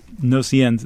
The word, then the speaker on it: Gnosiennes: Let's hear the second one of the Gnosiennes, and I'm sure I'm not pronouncing that right. – Gnosiennes.